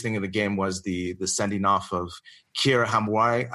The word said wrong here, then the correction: Hamraoui: I cannot say her name, Brian. We were practicing. – As we have established on this show, you do have certain pronunciation issues. – Yeah. – So this one I can. Kira Hamraoui. – Yeah, Hamraoui. That's where we go.